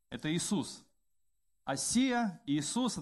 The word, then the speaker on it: Осия: Не Осия, а Осия.